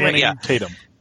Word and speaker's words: Channing Tatum: Okay, so basically it opens up with Channing Tatum. Is that how I say his name? – Channing Tatum.